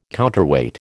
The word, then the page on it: counterweight: American English